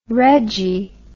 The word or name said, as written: reggie